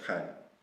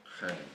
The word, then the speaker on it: Khadim: And plus, Kadim. It isn't wrong, but it's actually said Khadim. It's just Arabic.